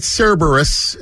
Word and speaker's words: Cerberus: By the way, who did this Brooklyn Cerberus? It's awesome. – That was from Nick Mesmer. – I love it, Nicky. It's a great job. Now, here's more, right? So I need you to take that Cerberus.